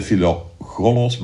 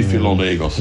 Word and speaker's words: Philogelos: Het is niet de Philogelos, maar de Philogelos. Gelos, ja.